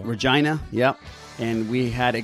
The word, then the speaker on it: Regina: First of all, Regina rhymes with fun.